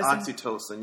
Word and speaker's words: Oxytocin: Oxytocin, yeah.